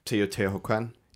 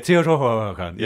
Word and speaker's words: Teotihuacan: Teotihuacan. – Yeah, Teotihuacan.